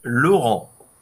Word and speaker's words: Laurent: Ah, in French, yeah. it's Laurent.